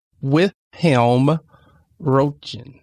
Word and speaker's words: Wilhelm Röntgen: So I'm going to, it is Wilhelm Röntgen.